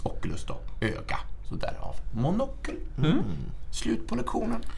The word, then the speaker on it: monocle: Ordet kommer från senlatinets monoculus, alltså enögd, via franskans monocle, eller monocle någonting. Jag kan inte franska uttalet. Monocle. – Monocle, ja.